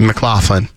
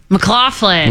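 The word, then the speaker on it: MacLachlan: Is that Kyle McGlanahan that says that? – Yeah, I mean, you almost said his name right, but yeah. – McClanahan? – McLaughlin. – McLaughlin. – Yeah.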